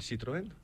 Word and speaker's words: Citroën: ¿El Citroën? – No, la pronunciación.